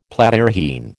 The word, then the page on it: Platyrrhine: American English